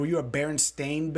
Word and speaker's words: Berenstain: No, Berenstain. Berenstain. Stain. I'm no Jewish people, and it's pronounced Steen.